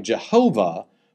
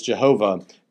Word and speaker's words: Jehovah: So if the Y were as a J and the W in our transliteration were a V, you might get something like Jehovah.